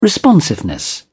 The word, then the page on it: responsiveness: British English